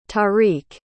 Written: taariq

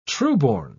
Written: trueborn

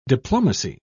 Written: diplomacy

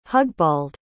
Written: hugbald

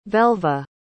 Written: velva

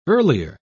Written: earlier

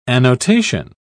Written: annotation

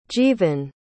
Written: jeevan

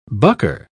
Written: bucker

